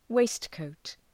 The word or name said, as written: waistcoat